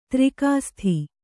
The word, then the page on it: ದರ್ಕಾಸ್ತ್: darkāst